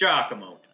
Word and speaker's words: Giacomo: Well, it's Italian. It's Giacomo.